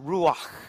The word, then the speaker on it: ruach: The word is ruach. It's one of those onomatopoeia words, right? That sounds like it is pronounced ruach.